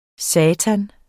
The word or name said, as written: satan